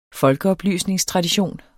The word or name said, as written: folkeoplysningstradition